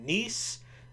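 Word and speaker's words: Kneese: I am your host, Sean Neese. The K is silent.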